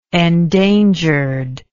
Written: endangered

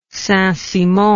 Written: saintsimon

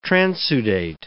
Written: transudate

